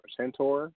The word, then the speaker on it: centaur: She's really, I mean, she's gorgeous, and especially when she became a centaur. Is that how you say it? – Yep.